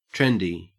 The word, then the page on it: trendy: Australian English